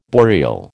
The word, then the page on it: boreal: American English